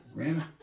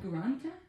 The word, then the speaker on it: Granta: Grana? – No. Granta? What are we talking about? – It's text porn. – Oh. – You'll see. – It's called Granta? – Yeah, sure.